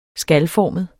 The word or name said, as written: skalformet